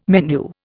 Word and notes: menu: Indian

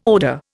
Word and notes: odour: Indian

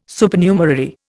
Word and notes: Supernumerary: Indian

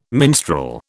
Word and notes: minstrel: British English

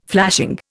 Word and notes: flashing: Indian

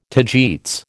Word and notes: tagetes: American English

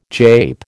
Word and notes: jape: American English

